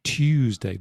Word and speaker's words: Tuesday: So for somebody from America, there's no difference between two, T-W-O, two, T-O-O, and then Tuesday or YouTube. – That's a good one as well. – When they have this T-U, they pronounce it like two, like the number two, but British people, I don't know why, we have this y sound.